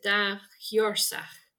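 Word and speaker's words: dà-sheòrsach: he said I would pronounce it da hiorsach.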